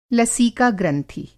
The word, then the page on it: लसीका ग्रंथि: Hindi